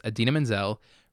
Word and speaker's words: Idina Menzel: the next performer is Idina Menzel, pronounced exactly as it is spelled, mm-hmm.